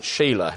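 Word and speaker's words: Shelah: Well, Phil said Sheller. I'm sure that's right. Some might pronounce it Sheila.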